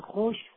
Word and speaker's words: خوش: در واقع در کلماتی مثل خوش، خوردن، خواهر، خیش، کار، خواب، خواب، خوابیدن و همه اینجا این واو وجود داره، واوی که تلفظ نمیشه اصلا.